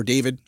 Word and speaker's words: D4VD: Okay, we're talking about D4VD or David again. – It's pronounced David, by the way. That's how he always pronounced it.